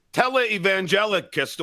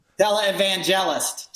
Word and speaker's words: televangelist: I mean, now you have a lunatic televangelicist, or whatever you call it. How do you pronounce it? – Televangelist.